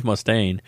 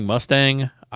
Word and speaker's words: Mustaine: To this day, I still don't know why, but he was implying that I was pronouncing his name wrong, but it's just Dave Mustaine. I don't know if he thought I was saying Mustang.